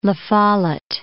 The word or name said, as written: La Follette